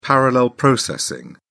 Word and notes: parallel processing: British English

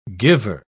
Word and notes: giver: British English

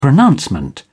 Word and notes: pronouncement: British English

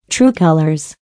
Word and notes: true colors: American English

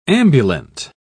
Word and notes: ambulant: British English

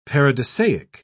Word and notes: paradisiac: British English

